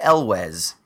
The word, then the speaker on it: Elwes: Is it Ewells? Ewells? – I always thought it was. Ewells? – It's a British name. Cary Elwes is what we said in America.